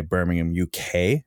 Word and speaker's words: Birmingham: or as you might say, Birmingham, UK. And I want to point out, I'm not pronouncing the H. It's not like Birmingham, Alabama.